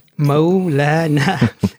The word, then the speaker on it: Maulana: because it's three syllables. It's like Maulana, as opposed to like imam or sheikh. It's just like one or two.